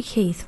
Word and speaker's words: Keith: Toby Keith rhymes with teeth.